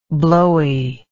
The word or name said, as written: blowiness